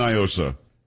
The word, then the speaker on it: NIOSA: Do not, because it's definitely Niosa. It is not a Spanish word. What does Niosa mean in Spanish? Nothing. – So, I don't know. – Well, that's the whole argument. They try to pronounce it like it's Spanish. It's not. It's an acronym. It's, and for those around the country, it's Night in Old San Antonio, and here we've always said Niosa, and for some reason, like the Joaquin Castros of the world think you've got to pronounce it like it's a Spanish word.